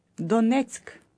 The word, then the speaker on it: Донецьк: Донецьк. Донецьк.